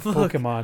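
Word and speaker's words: Pokémon: It's got the accent over the E that makes it Pokemon.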